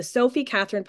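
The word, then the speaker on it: Sofie Katherine: So it was initially the Changing the Narrative podcast, but I really felt like I was supposed to rebrand that into the Sophie Catherine podcast.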